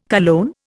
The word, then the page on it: cologne: Indian